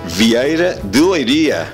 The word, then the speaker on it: Vieira de Leiria: Bueno, queridos radioescuchas de Radio Rueda, hoy estamos transmitiendo desde Vieira de Leiria, o algo así sería la pronunciación en español, pero la pronunciación en portugués, ¿cómo es exactamente? – Me has apañado ahora, no estaba a espera de que me cuestionases algo. – Ah, pero lo agarré distraído. Entonces, ¿cómo se pronuncia? Vieira de Leiria. – Muy bien, Vieira de Leiria.